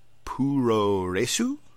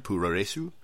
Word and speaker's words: puroresu: Puroresu, I believe is the term. Puroresu, Japanese wrestling, pro res as it's called.